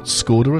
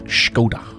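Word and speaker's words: Škoda: It's not Skoda at all, it's Skoda with a shk. Skoda.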